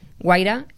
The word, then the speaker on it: Wayra: Y, bueno, cualquiera puede contactar a través del blog de Guaira. Guaira se escribe WAYRA.